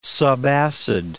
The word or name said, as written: subacid